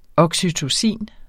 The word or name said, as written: oxytocin